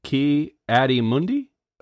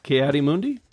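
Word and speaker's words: Ki-Adi-Mundi: Ki Adi Mundi? – That's right. – Ki Adi Mundi.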